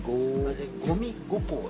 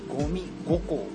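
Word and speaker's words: 五味五香: ゴミ五香、五つの味、五つの香りと書いてゴミ五香というお店ですね。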